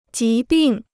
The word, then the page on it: 疾病: jí bìng